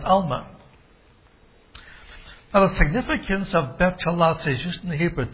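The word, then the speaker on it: betulah: But another key word to deal with is the word Betulam. I'll spell it for you. B-E-T-U-L-A-H. B-E-T-U-L-A-H. Betulam.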